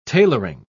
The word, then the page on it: tailoring: British English